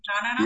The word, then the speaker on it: Ra'anana: Did I even say that correctly? Ra'anana? – Yes, yeah, you said that perfectly.